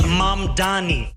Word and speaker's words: Mamdani: Aber eigentlich heißt er The name is Mamdani. – Zoran Mamdani. – M-A-M-D-A-N-I.